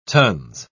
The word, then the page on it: tons: British English